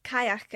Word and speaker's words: Cailleach: We know the Cailleach, or Kayak as it's pronounced in some parts of Scotland, as an ancient Gaelic environmental deity who built the mountains of Scotland.